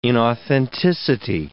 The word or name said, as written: inauthenticity